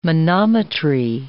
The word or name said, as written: manometry